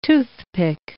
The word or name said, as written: toothpick